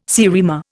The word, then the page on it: seriema: Indian